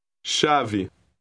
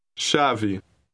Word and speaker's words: chave: Chave. Chave. – Note the ending. It has a short E sound.